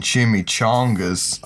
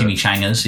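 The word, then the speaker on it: chimichanga: When he says like, "Let's make some fucking chimichangas," I thought, "Come on." – Man. There was some stuff in there that Is that how you pronounce it? I've only read that. – Chimichangas. Yeah. – Chimichangas.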